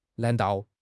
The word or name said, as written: nan2 dao4